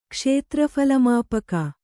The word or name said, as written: ಕ್ಷೇತ್ರಫಲಮಾಪಕ